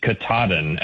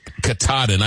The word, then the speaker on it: Katahdin: And I'm just going to, off the top, say that the pronunciation is Katahdin, actually. – Katahdin. I knew I was going to butcher it.